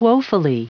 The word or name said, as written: woefully